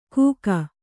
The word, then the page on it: ಘೂಕ: kannada